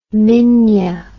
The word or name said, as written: minya